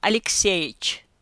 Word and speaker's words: Alexeyevich: Characters: Ivanov Nikolai Alexeyevich, colloquial pronunciation Alexeyich, short familiar form Kolya, endearing form Nikolasha, Anna Petrovna, Anyuta, Anya, both short familiar forms.